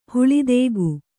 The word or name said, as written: ಹುಳಿದೇಗು